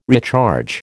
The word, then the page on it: recharge: American English